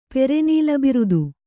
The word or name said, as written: ಪೆರೆನೀಲಬಿರುದು